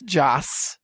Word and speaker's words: Joss: Josh Whedon? – That's Joss.